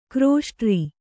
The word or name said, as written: ಕಿರುಸೊಡರ್